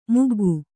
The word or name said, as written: ಮಗ್ಗ